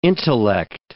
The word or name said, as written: intellect